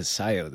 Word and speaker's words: Scio: when we went through that on our way, incidentally, to SIO this time around. – Right. SCIO. SIO. – It's pronounced SIO, too. I totally didn't even think about how hard it was.